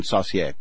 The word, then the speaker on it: Saucier: Is it Saucier? – I believe Saucier? It's the French pronunciation, Saucier.